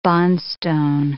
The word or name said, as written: bondstone